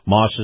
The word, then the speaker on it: Maus: Moss is spelled M-A-U-S.